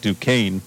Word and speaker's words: Duquesne: Yeah, unless somebody asked us earlier, you know, how do you pronounce Duquesne? And you know, we said Duquesne, but it doesn't look like that. But that's okay. That's just how it's pronounced.